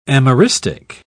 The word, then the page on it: ameristic: British English